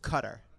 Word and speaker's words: Qatar: There's that country, Qatar, or it's pronounced Cutter. – Cutter. But I've heard it pronounced both ways.